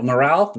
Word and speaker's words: Miral: Miral? Miral Miral Miral. – that is correct.